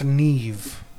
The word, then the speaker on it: Neve: It's pronounced Neve.